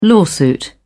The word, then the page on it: lawsuit: British English